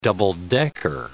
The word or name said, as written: double-decker